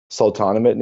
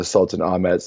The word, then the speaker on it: Sultanahmet: Firstly, to start off, I think everyone needs to be looking at the Sultanahmet neighborhood. That's mm-hmm. how you say it colloquially, but it's named after the Sultan Ahmet. So it's literally Sultan Ahmet.